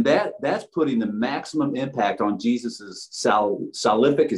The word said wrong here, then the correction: salvific: is that how you say it? – Salvific, work.